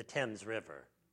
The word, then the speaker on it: Thames: I got to pronounce that like the Canadians do. The Thames River.